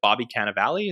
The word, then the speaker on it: Cannavale: And this is Uncle Tommy, who's played by, is it Bobby Cannavale? Is that how you pronounce it? – Yeah.